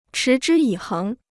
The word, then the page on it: 持之以恒: chí zhī yǐ héng